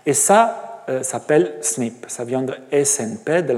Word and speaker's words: SNP: Mais très important, tout au long du jour aujourd'hui, du cours aujourd'hui et de, la reste des cours, vous allez m'entendre parler de SNP, prononcé comme ça, SNP.